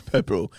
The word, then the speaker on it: Pepperell: Pepperell. – Yeah, P-E-P-P-E-R-E-L-L.